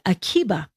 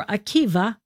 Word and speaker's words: Akiva: It's spelled with a B or a V, Rabbi Akiva or Akiba. So it's a Vita, but it's the Greek B is pronounced with a V.